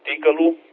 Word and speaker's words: Tcl: Că Tickle-ul, cum se pronunță, că așa am înțeles că se pronunță, se scrie TCL, este un limbaj de programare care nu este foarte cunoscut, dar nici nu.